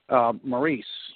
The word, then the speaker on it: Maryse: Maryse, did I pronounce it correctly? – I think so. – Yes.